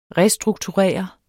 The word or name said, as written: restrukturere